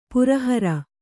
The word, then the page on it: ಪರಿಹರ: pari hara